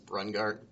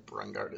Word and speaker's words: Brungardt: My last name is Brungardt. Brungardt is spelled B-R-U-N-G-A-R-D-T.